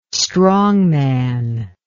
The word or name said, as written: strongman